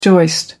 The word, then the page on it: joist: American English